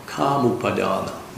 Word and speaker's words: kāmūpādāna: He calls this type of upādāna kāma, kāmūpādāna, with a long A.